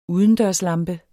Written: udendørslampe

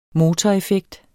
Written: motoreffekt

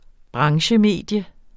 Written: branchemedium

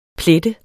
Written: PLETTENDE

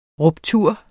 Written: ruptur

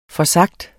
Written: forsagt

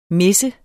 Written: mis

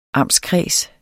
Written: amtskreds

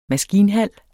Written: maskinhal

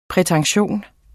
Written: prætention

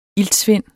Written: iltsvind

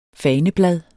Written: faneblad

